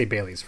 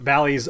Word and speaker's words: Bally's: Bailey's, it's spelled B-A-L-L-Y. So I'm going to go Bally.